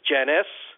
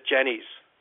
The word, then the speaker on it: Jenes: He was drafted by Minnesota, and his name is Janice, but he likes it Jenny's. – Okay. – Right? So in the pronunciation guide, it'll say J-E-N-I-S. But it's really J-E-N-E-S, Jenny's.